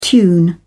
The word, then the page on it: tune in: British English